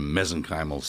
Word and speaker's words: mesenchymal: They know what a mesenchymal stem cell or mesenchymal, how do you say it? – Mesenchymal, yeah.